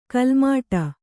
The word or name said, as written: ಕಾಲುಮೆಟ್ಟು